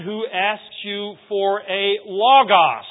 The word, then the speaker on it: logos: The word logikos, which is used here and translated as reasonable in the King James translation, comes from the Greek word logos, or sometimes pronounced logos, L-O-G-O-S.